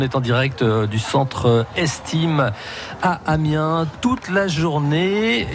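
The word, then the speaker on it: S'time: Alors ça s'écrit S apostrophe. – Oui. – T-I-M-E, comme Estime, on aurait tendance à penser à de l'anglais, mais en fait, c'est un jeu de mots sur le mot estime.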